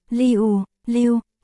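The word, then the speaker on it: liu, liu (六): Gốc là 666, trong ngôn ngữ mạng của Trung Quốc nghĩa là tuyệt vời, đỉnh cao, vì số 6, phát âm là liu, liu, nghe giống từ liu, liu, có nghĩa là trơn tru, hoặc thuần thục.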